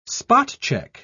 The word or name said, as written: spot-check